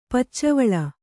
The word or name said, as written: ಪಚ್ಚವಳ